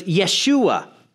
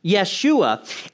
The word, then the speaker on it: Yeshua: In Hebrew, his name was Yeshua. Yeshua.